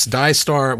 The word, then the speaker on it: DAIISTAR: Die Star, which is, by the way, is spelled DAIISTAR, because if you don't have a clever spelling, it won't look cool on the display on your Tesla, right?